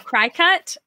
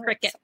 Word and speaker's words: Cricut: It's called a Cricut. Or a cry cut. I think it's pronounced cricket, though.